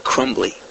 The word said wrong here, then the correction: Cumby: Cumby. Boy, I really butchered that one.